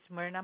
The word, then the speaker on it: Myrna: My name is Myrna Manalastas. – May I have you spell that? – M-Y-R-N-A.